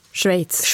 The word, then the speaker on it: Schweiz: Och du säger det helt perfekt. Schweiz, det är inte helt lätt. – Ja. Ja. Ja. – Ett ord med många uttal.